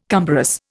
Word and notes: cumbrous: Indian

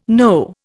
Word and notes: know: Indian

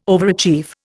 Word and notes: overachieve: Indian

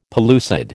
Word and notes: pellucid: American English

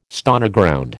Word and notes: Stoneground: American English

